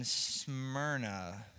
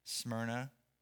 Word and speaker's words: Smyrna: And uh, this is the letter written to the church in Smyrna. S M Y R N A, Smyrna. You guys say Smyrna? Smyrna. You can say it like that if you want.